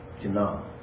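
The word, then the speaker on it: qin'ah: It's pronounced kin-a, but it's spelled Q-I-N apostrophe A-H, kin-a, something like that.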